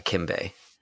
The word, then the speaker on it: Kimbei: Yeah, K-I-M-B-E-I, Kinbe.